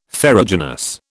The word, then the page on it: ferruginous: British English